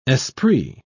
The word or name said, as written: esprit